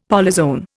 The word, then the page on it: polyzoan: Indian